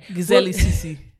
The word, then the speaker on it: Gyzeledzc: It was from, I don't even know how to say this, G-Y-Z-E-L-E-D-Z-C, if you can pronounce that, go for it. – Gizelli C-C. – Perfect. Thank you very much.